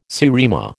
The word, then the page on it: seriema: American English